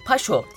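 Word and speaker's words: pas chaud: Fransızcadaki paşa'dan geliyor aslında. Paşo diye okunuyor aslında.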